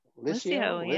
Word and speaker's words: Lisio: So you're at Lisio. – Yeah. – Did I say it right? – Lisio. Yeah. Yeah, Lisio means delightful in Italian, or smooth in Italian.